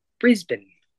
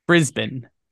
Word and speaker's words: Brisbane: Okay, Kyle, we're gonna give you a lesson today in pronunciation. – Okay, let's do it. Yeah. Because you were making fun of me. – After me. Repeat after me. Brisbane.